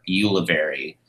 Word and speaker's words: Ulivieri: Um, and my Instagram handle is Nick underscore Ulivieri.